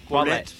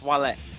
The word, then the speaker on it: Ouellet: Ouellet. – Ouellet. – Remember, you got to French it out, dude. It's Ouellet.